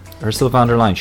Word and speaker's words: Ursula von der Leyen: Ursula von der Leyen.